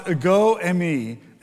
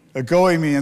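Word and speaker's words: ego emi (ἐγώ εἰμι): So it's actually the emphasis is on the second syllable. So it's more appropriately pronounced ego emi. Ego emi. And so that's how you say it in the Greek.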